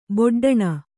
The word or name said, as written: ಬೊಡ್ಡಣ